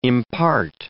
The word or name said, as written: impart